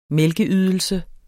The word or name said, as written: mælkeydelse